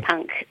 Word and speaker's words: PUNQ: Or is your pronunciation, how would you pronounce that acronym? – PUNQ. – PUNQ. There you go. – PUNQ is a Q, so Pop Up North Queensland. But PUNQ, because it's got a bit of a PUNQ edge to it.